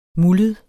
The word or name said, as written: muldet